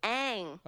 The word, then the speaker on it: Aang: It's Aang.